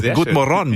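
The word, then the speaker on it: god morgon: Good Morgon, muss ich sagen. Okay, Betonung auf der letzten Silbe.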